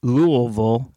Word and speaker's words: Louisville: First, he's from Louisville. And he showed how to pronounce it. Louisville. – Louisville. – Yeah, say that with speech stuff. You say you got a shot of bourbon in your mouth.